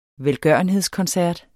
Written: velgørenhedskoncert

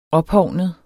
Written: ophovnet

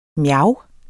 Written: mjav

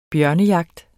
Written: bjørnejagt

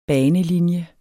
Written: banelinje

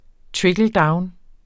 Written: trickle-down-

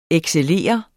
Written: excellere

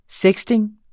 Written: sexting

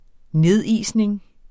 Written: nedisning

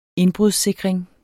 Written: indbrudssikring

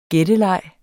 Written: gætteleg

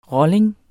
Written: rolling

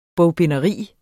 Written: bogbinderi

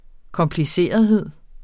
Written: komplicerthed

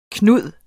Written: Knud

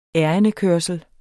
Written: ærindekørsel

